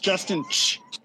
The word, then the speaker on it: Justin Che: It's pronounced Justin Che.